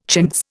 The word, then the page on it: chintz: Indian